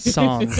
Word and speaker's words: sans: If you would have said I would have given it to you sans parentheses. Yeah. Yeah. You did not say I. – Sans. You got to get in the sans. – Songs, actually, is how that's pronounced.